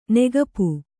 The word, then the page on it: ನಗಪ: nagapa